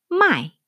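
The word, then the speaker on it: 卖: 어, 근데 이제 재밌는 게 이게 마이 하면 삼성으로 읽으면 어, 사다예요. 저희가 뭐 물건을 샀다 이렇게 할 때 사다. 근데 물론 한자는 다르지만 발음은 같아요. my, my, 그러니까 my, my. 그 대신 성조가 다르죠. my 사성으로 읽으면 팔다예요. 상인이 되는 거죠. 내가 고객으로 살 때는 my, 그 다음에 내가 물건을 파는 상인이 될 때는 my 가 되는 거예요.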